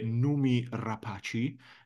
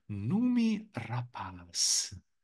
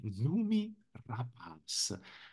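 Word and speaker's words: Noomi Rapace: il suo nome si pronuncia Numi Rapaz. E l'ho trovata una cosa bellissima, lei è svedese, quindi Numi Rapaz.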